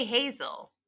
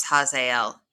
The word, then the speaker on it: Hazael: He sends a man named Hazael. Uh-huh. And if I say Hazel, just know I said it right this time.